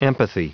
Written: empathy

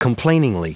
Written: complainingly

